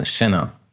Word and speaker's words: Chenin: Chenin? CH vorne. Chenin.